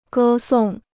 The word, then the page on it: 歌颂: gē sòng